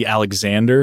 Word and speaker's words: Aleksandar: Yeah, a lot of people struggle with the Aleksandar because the way it's spelled. – Yeah, okay. – It's not what they're used to. So I just go with Alex because, I mean, people call me Alec. I've heard Aleksandra before.